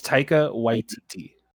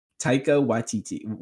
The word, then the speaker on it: Taika Waititi: It's just, how do you pronounce his name? I don't want to keep ruining it like I did Rian Johnson at first. – Taika, it's Taika Waititi. – Taika Waititi.